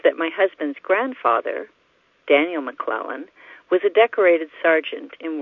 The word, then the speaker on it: McClellan: It is pronounced McClellan, which rhymes with melon.